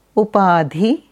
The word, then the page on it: Upadhi: Sanskrit